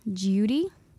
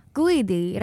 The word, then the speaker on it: Guidi: Judy. – Guidi.